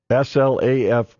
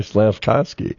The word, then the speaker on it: Slavkovsky: That's Y-U-R-A-J, Juraj. Slavkovsky, Slavkovsky. – Slav. – Yeah. Or you can just say Slav. You're right, Slavkovsky. I had to phonetically write that in my sports year. I put Y-R-R as in your.